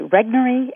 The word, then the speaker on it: Regnery: Clarence Mannion was one of them, but there were also two others, William Rusher and Henry Regnery. Am I saying that correctly? – That's right. Yes, these are the main characters of the book.